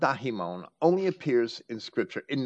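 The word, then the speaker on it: daimon (δαίμων): That's how I would pronounce that, daimon. Some scholars might pronounce it daemon.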